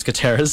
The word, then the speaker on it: Guterres: And then on Wednesday, the UN Secretary General Antonio Guterres I looked up the pronunciation before, but I'm pretty sure it's Guterres, sorry.